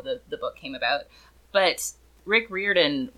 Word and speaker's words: Riordan: So, his name is pronounced Rick Riordan, according to Google.